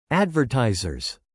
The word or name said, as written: advertisers